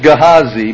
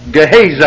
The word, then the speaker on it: Gehazi: I used to pronounce it Gehazi, but I was corrected. Gehazi.